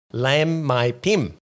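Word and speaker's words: Laem Mae Phim: Lam Mai Pim. – Silent H.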